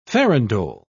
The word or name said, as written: farandole